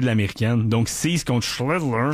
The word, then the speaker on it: Schlittler: Et il se bat présentement avec un duel impitoyable, je dirais, avec Cam Schlittler. – Schlittler. J'aime ça, mais tu l'as très bien prononcé.